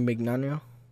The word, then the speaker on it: Mignano: Mr. Mignano? – I don't know how to say his last name. That's why I said Mr. Vic. – Mignano? – Mignano. – It's like Flo Mignon.